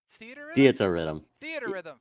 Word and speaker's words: Theatrhythm: This is a theatrhythm? How do you say that? Theater rhythm.